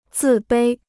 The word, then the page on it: 自卑: zì bēi